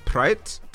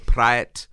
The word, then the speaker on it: Praet: So I've gone for Denis Praet to Arsenal, or Praet, it actually should be pronounced. I did that incorrectly.